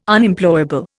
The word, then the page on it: Unemployable: Indian